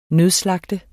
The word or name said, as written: nødslagte